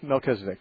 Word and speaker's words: Melchizedek: But I have a question about this high priest Melchizedek. I might not be saying it right. – Mm-hmm. It's close. Melchizedek, yeah.